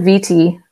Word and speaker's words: Vitti: It's Alyssa Vitti, last name V-I-T-T-I.